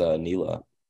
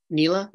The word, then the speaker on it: Nila: The last name is pretty close, I feel like. But I think her first name is Nila. – Nila.